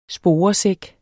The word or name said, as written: sporesæk